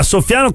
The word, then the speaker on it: Soffiano: Ma insomma, se scambi l'accento viene fuori un verbo, no? – Cioè, soffiano, esatto.